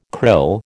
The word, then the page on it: krill: American English